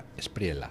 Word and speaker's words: Espriella: Él lo pronuncia Espriella. – Pues si él lo pronuncia así...